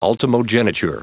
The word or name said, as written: ultimogeniture